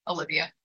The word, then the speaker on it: Olyveya: So I'm everywhere at Carnivore Ballerina, except for Twitter, which is just at Olyveya with an extra A, and that's spelled Olyveya. So that's how my name is spelled, but it's just Olyveya.